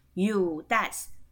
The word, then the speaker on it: yutas: 或者是你也可以父啊，也是叫哑巴，哑巴我们刚刚讲的嘛，好爸爸，好或者是阿公啊，祖祖祖父啊，就叫 you das y u t a s，好，好那读音的部分呢，y 是念也，u 是念物，you you t a s das，t 是念的，好，t 是念的啊。